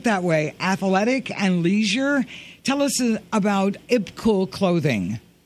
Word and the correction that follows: IBKUL: It's called IB Cool. – IB Cool. – Spelled, oh. – Yeah, it's spelled IBKUL.